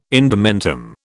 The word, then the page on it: indumentum: British English